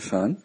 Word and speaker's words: Sean: La dirección es, como en inglés, Padre Shan, se dice Father Shan. Es la abreviación de Padre, FR. Entonces, es FR.SEAN, que así se escribe mi nombre, Sean, es Juan en irlandés.